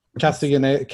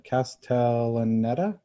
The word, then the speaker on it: Castellaneta: Castellaneta. Castellaneta. Castel, uh, Castellaneta.